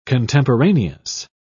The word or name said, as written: contemporaneous